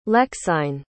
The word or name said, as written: lexine